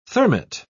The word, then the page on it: thermite: British English